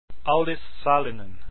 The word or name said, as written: sallinen